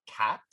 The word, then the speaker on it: qat: I'm also going to talk about cat, which is spelled, for those of you who cannot read my notes right now, Q-A-T, pronounced like the animal.